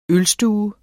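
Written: ØLSTUERNES

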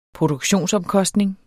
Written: produktionsomkostning

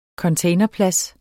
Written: CONTAINERPLADSER

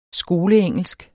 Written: skoleengelsk

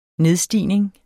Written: nedstigning

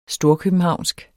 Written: storkøbenhavnsk

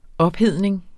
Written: ophedning

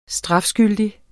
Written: STRAFSKYLDIGT

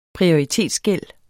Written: prioritetsgæld

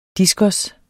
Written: diskos

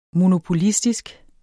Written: monopolistisk

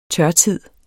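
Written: tørtid

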